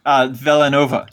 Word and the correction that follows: Villeneuve: No, I do know it because people got really mad at us on the Dune podcast and wrote in several times with clips of how to pronounce his name. Denny Villeneuve.